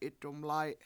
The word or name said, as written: 7itdumlAy7